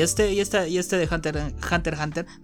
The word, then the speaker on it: Hunter x Hunter: No sé por qué la gente cuando yo empecé a ver el anime, todos le pronunciaban Hunter x Hunter, ¿sabes? Porque hay una X en medio y después me enteré que era Hunter Hunter por una amiga que me dijo qué porquería estás hablando, se pronuncia así.